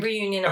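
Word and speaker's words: Réunion: Reunion okay.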